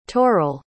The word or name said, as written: turrell